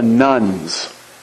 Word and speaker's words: Nones: The book is entitled Rise of the Nuns. Now, this is not about Catholic women in robes and habits and things. It's spelled N-O-N-E-S, nuns.